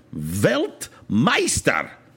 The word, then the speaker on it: Weltmeister: So if you want to speak like a Weltmeister, no, I said that wrong, Weltmeister, perhaps, German friends, that means a world master, all right, or master of the world of soccer.